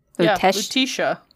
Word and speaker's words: Lutetia: I swear to God, before we started, Lutetia. – I think that's right. – Lutetia.